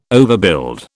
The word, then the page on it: overbuild: British English